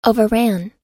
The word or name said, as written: overran